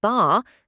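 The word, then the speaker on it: bar (八): The number 8, pronounced bar, sounds similar to the word fa, which means to prosper or to become wealthy.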